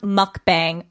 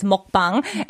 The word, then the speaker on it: 먹방: It is not pronounced mukbang. Oh my gosh, that's my biggest pet peeve when I see that. It's mukbang.